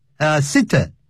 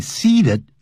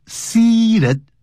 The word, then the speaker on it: seated: 请呢留在座位上面 remain seated 常常听的一些乘务员呢会念成 remain seated 好这个发音呢就不行了啊叫 remain seated seated 发 e 的长音好这样子好我们接着再看实用句啊 useful expressions 工作量 1.